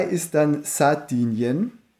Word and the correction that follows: Sardinien: Schön auch deine Betonung. Aber, Sardinien. – Ja. – Sardinien.